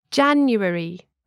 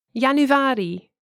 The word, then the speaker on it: januari: In English, nouns such as months normally have the stress on the first syllable. – January, February, etc. – In Dutch, the stress is usually on the second to last or penultimate syllable. – Januari, februari, etc.